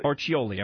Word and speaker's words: Orcioli: And Mary was kind enough to track down Tony – how do you say it? Tony Orcioli? – Orcioli. – I'm sorry. I missed a syllable in there.